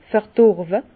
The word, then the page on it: verdorwe: Bas-Rhin; Herrlisheim